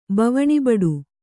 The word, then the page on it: ಬವಣಿಬಡು: kannada